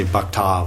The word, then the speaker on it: bhakta: If you're real Vishnu bhakt, Krishna bhakt, and we say bhakta, like, it's not a long A anyway, it's bhakta, bhakta. Yeah, it's short, bhakta.